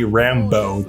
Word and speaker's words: Rambeau: You guys are definitely going to be in for a treat hearing from my, I guess, now good friend, Miss Ruby Rambeau.